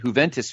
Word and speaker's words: Juventus: Juventus of Italy and Liverpool of England.